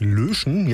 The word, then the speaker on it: Löschen: Da haben wir einmal den bei Drebkau, der heißt richtig Löschen, ja, mit einem Ö. Und ausgesprochen wird der, so wie man was am Computer in den Papierkorb verschiebt, löschen.